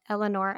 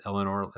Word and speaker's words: Elanor: It's we spelled it Elenor as in Eleanor Roosevelt, whereas Elenor the flower is just E-L-A-N-O-R. Eleanor Roosevelt is E-A-N-O-R.